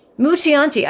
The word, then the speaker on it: Mucciante: Do you know how to say this name? This is the movie producer who was working with Siebold on Lucky. – Mucianti? – Is that how... Say it again? – Mucianti? – Mucianti.